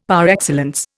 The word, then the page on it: par excellence: Indian